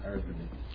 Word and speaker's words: Irving: Edward Irwin. Is that how we decided to pronounce his name? – Yeah, Irwin.